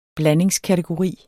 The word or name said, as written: blandingskategori